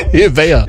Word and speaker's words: Nivea: Someone says their mate says it with a silent N. – Ivea.